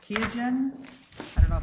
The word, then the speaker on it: Qiagen: Yes, it's terrible. It's Qiagen is how you say it. – Qiagen, thank you.